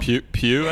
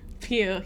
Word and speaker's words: Pugh: What did I say, like Pug? I don't know. – Pew, I think maybe. – Pew, yes, I'll take that.